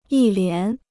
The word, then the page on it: 一连: yī lián